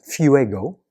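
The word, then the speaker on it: phuego (φεύγω): In Greek, it means, it's pronounced phuego.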